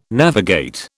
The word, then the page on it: navigate: British English